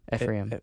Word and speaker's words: Ephraim: Ephraim.